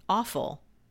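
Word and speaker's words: offal: Awful. It's O-F-F-A-L.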